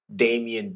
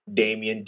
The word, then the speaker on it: Damien: My Instagram handle is Damien D. Smith, which is basically my full name. Damien, D-A-M-I-E-N-D Smith. Again, my bad, I didn't spell it. D-A-M-I-E-N-D-S-M-I-T-H.